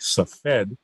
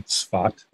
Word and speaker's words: Tzfat: The main towns in Galilee historically are where I live, which is a kind of the mystical Kabbalah capital that in English looks like Safed, but you'll hear me pronouncing it in the Hebrew way, Tzfat, and Tiberias, which is on the Sea of Galilee.